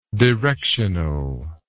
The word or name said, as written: directionally